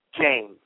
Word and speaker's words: James: My name is James. – Hi. That line cut out a little bit. Was that Dave? – James. – I'm sorry, could you spell that, please? – Sure, J-A-M-E-S.